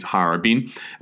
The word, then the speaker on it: Harbin: Harbin, today pronounced in Chinese, Harbin,